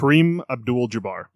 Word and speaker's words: Kareem Abdul-Jabbar: Like there's a bit where he's talking about Jabdul Adar, who I wish yes. – I knew. I wish I was confident I was pronouncing his name correctly. – Kareem Abdul-Jabbar.